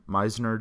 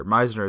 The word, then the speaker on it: Meisner: Mine personal one is at Meisner Jordan. My last name, or Meisner is spelled MEI.